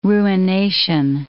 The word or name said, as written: ruination